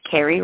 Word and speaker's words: Carri: and you send me an email at Coach Carrie Roldan. I'm going to spell my name for you. It's Coach C-A-R-R-I. – Oh, I think I pronounced it wrong.